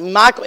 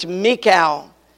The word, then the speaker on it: Michal: And Michal, that's the way that's pronounced. I've always pronounced it Michal, it's Michal.